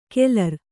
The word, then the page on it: ಕೆಲರ್: Kannada